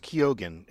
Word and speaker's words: Keoghan: and it's apparently pronounced Keoghan. Apparently in Ireland, it's normally pronounced Keoghan, but he's from Dublin and they pronounce it Keoghan, just in case you want to know.